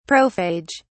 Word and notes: prophage: British English

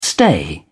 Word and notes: stay: British English

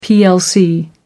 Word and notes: plc: American English

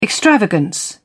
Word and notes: extravagance: British English

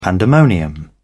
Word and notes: pandemonium: British English

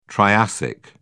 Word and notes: triassic: British English

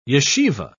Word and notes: yeshiva: British English

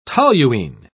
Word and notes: toluene: British English

